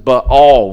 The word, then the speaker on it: Baal: Ironically, Judah had fallen into Baal worship, or some would pronounce it Baal worship.